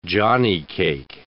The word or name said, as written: johnnycake